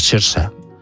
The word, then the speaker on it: Saoirse: que como es gaélico es Saoirse. Es como se pronuncia.